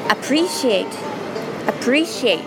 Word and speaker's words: appreciate: Appreciate、ちょっと発音難しいですけれども、 Re というところをアクセントつけてみましょう。 Appreciate、 Appreciate、 I appreciate you so much。